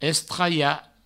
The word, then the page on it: estralhar: Provençal; estraia